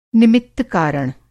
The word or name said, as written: निमित्त-कारण